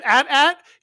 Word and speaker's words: AT-AT: So you're just with AT-AT, it's double syllable, you're rocking and rolling. That's how the troopers say it.